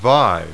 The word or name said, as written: vie